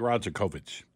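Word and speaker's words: Rajaković: Sounds good. I like Rajakovic. – Yeah. Okay. Rajakovic? – Rajakovic. – Okay. – Yeah, because the other one, you can get in trouble, maybe. You mispronounce it just by a tad.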